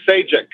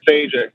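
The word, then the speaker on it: Sejić: Um, now, here's the question can you help me pronounce the name of the writer slash artist of this book? – Stephen Sajic. He's Croatian. – Stephen Sajic? – Sajic. That's just go with it and he'll forgive us.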